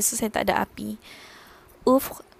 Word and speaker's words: ouvre: So this one is pronunciation is uf macam bila korang mengaji kan yang uh, contohnya qaf bertemu ba dengan lam jadi qab kan. So ini macam uf dia macam v bertemu dengan r bertemu dengan i.